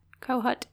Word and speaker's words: Kohut: Kohut? K-O-H-U-T. How would you say that? – Kohut, yeah.